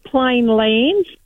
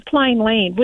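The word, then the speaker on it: Plainland: I always call it plain land. But it's actually plain land, which doesn't sound right. – There's no S.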